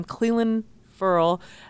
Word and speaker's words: Clelin Ferrell: heard the name Clelin Ferrell, and that is how we pronounce his name. We had him on a conference call. Somebody asked, that is how you say it. It's like Clelin and then Ferrell, like so it's a little different than like saying Will Ferrell or Ferrell, yes. Like Scott Ferrell.